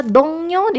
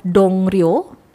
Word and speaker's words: 동료: kenal dengan banyak orang ya contohnya 제 동료가 발이 넓어요 제가 물어볼게요 제 동료가 발이 넓어요 제가 물어볼게요 ya ada kata dongnyo di sini ya tulisannya adalah dongryo ya dongryo pakai o tidur ya dongryo tapi dibacanya dongnyo. Realnya itu berubah menjadi nian ya, jadi n bunyinya.